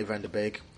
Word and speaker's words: van de Beek: Yeah, so I'm kind of excited about Donny van de Beek. I'm not even sure if I'm saying his name right. – Yeah, that's how you say it.